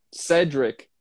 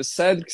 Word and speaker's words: Cedric: C-E-D-R-I-C. Cedric. Mas eu sempre digo, pessoal, pode chamar como, como tiver mais confortável. Cedric, Cedric, né? César, José, tanto faz. Por mim funciona, tá tranquilo.